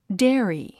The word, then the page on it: dairy: dé ə ri; デェアリィ